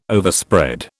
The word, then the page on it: Overspread: British English